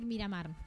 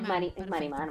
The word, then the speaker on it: Marimar: Marimar, lo dije bien, ¿no? Porque siempre estoy a punto de decir Miramar, pero lo dije bien. – No, es, Marimar, Marimar.